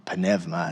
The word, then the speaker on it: pneuma: Or pneuma, that's actually how they say it.